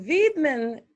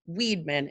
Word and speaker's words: Wiedmann: Wiedmann, it's German. I'm German. So that's the German way of saying Wiedmann is the last name.